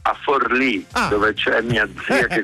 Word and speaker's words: Forlì: Ieri ero a Palermo, poi sono stato vicino ad Ascoli Piceno, adesso sto a Forlì, poi a Bologna scendo, prendo un altro treno e arrivo a Mondovì, quindi tutti i posti con l'accento sulla I.